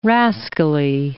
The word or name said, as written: rascally